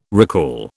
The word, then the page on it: recall: British English